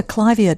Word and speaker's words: Clivia: On the Clivia clivia debate, yep. – We had a note from Eve in Jandakot who said, hi, Clivias were named for Mrs. Clive, so they are pronounced Clivia.